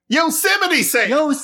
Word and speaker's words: Yosemite Sam: That's not correct. – No? – Your voice. – It's Sam. Something Sam. Let me see it. You're not saying it right. It is something Sam. Yosemite Sam.